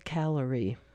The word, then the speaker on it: Callery: And again, the singer is Phil Callery. That is spelled C-A-L-L-E-R-Y, not calories as in the things we eat too many of.